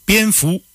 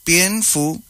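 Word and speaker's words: Pien fu (蝙蝠): Pien fu. – ¿Cómo? – Pien fu. – Pien fu.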